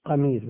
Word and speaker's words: قمير: وزوج مسروق، زوجة مسروق تابعي الجليل، زوجته اسمها قمير، قمير بفتح القاف.